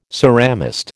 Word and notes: ceramist: American English

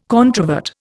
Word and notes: controvert: Indian

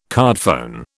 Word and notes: cardphone: British English

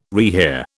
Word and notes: rehear: British English

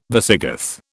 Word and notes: Visigoth: British English

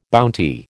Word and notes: bounty: American English

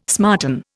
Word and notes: smarten: Indian

